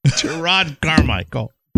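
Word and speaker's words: Jerrod Carmichael: earthquakes and the Golden Globes and Gerard Carmichael.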